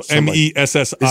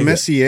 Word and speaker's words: Messi: So M E S S I. – Is it Messier? Is that how you pronounce it? Or Messi. – No, it's Messi.